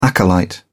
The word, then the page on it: acolyte: British English